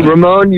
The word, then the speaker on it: Ramon: You have to roll your R's when you say that. – Ramon, you're one of the legendary swordsmen.